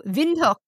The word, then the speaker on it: Windhoek: But, you know, Windhoek is the capital of Namibia. – Oh, really? – It's W-I-N-D-H-O-E-K. – And you say it like a V? – Yeah. – Windhoek. – Windhoek.